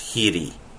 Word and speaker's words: hri: That's the Wikipedia link, which is working from the word hri, H-R-I. In Sanskrit, it's hiri. Hiri. In Pali, it's hri. But it's not the same hri as hri, or the Tibetan mantric syllable.